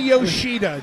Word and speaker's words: Yoshida: Masa Yoshida.